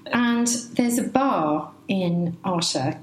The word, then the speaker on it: Artá: We live in the north of the island in a town called Arta. Am I saying that correctly? – Arta.